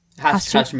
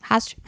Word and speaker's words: Rast: É, e o seu substituto, o René Rast, Rast, mesmo. – Rast.